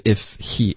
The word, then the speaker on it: if he: 那 if he 可否读成 if he，这个就是这么发的，对，就一般就是 if he h 也省略，就 if he。